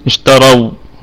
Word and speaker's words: اشتروا: اشتروا فنقول بالسكون، وجه بالسكون اشتروا، لكن يعني هذا الألف الذي ترونه بعد الواو هذا يسمى ألف فرقة، ألف فرقة يعني لا ينطق لا يلفظ، لا يجري بها، لا يجري بها التحريك، أيوه أي نعم فرقة التحريك.